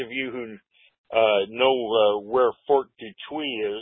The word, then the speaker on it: Detroit: We currently pronounce it Detroit. But it's still spelled the same way. It's Fort Dutuy. There is a, that's the way the French pronounce it, you know.